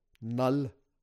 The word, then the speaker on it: Knull: Und auch diese, dann kam, es wurde ja im Vorfeld angekündigt, dass, auch witzig, ich habe im Film erfahren, dass dieser große Symbionten-Gott Null ausgesprochen wird. Geschrieben wird er K-N-U-L-L. Ich kannte den bis jetzt nur aus den Comics und ich habe mein ganzes Leben lang gedacht, der Typ Null. Heißt Knull.